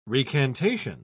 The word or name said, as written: recantation